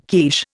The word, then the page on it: quiche: Indian